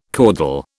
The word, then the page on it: caudal: British English